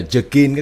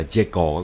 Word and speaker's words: Jekyll: Cái chữ này tiếng Anh đọc là Jekyll các bạn.